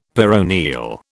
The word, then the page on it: peroneal: British English